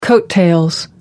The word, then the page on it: coat tails: American English